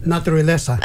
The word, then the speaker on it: naturaleza: Naturaleza. – Naturaleza.